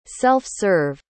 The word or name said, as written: self-serve